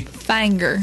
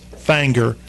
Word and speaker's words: Finger: Finger, Tennessee. Or as they say locally, Fanger. – Fanger. Spelled F-I-N-G-R, pronounced Fanger.